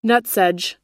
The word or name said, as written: nutsedge